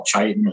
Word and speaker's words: chitin: the outer layer they call chitin or chitin, depending on your pronunciation.